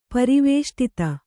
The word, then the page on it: ಪರಿವೇಷ್ಟಿತ: pari vēṣṭita